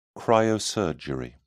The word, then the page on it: cryosurgery: British English